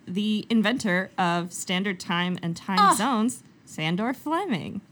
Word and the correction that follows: Sandford Fleming: My boy, Sir Sandford Fleming. – Yeah. – Love that guy. – Also said his name wrong right off the top.